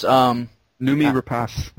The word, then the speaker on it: Noomi Rapace: Well, I mean, we're talking about that, and then also the actress who was starred in the original films, how is her name pronounced? – Noomi Rapace.